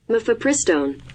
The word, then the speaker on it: mifepristone: Mifepristone. I may have said it wrong. Is That's the, close enough. Yeah, is the name here? I got it right here. – Mifepristone.